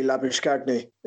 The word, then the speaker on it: Labuschagne: Point of order is actually Labuschagne, if you pronounce it in his native South African.